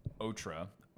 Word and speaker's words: Ottara: It's going to be Ottara, O-T-T-A-R-A. Middle name is Sacha, S-A-C-C-A. Last name is Pyne, P-Y-N-E.